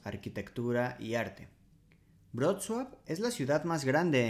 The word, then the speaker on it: Wrocław: Wrocław es un nombre difícil de pronunciar para los extranjeros, especialmente para hispanohablantes porque en su nombre tienen una L como con un palito que se pronuncia en polaco como UO. La pronunciación correcta es Wrocław, pero algunas personas eh, de España la conocen como Breslavia, que digamos es la traducción literal.